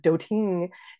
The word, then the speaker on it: doting: So we know right there, because the word doting is not pronounced that way, we know that that is a trochee.